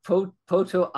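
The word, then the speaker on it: Potoa'e: Potoae, I think is how his name is pronounced.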